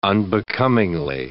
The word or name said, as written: unbecomingly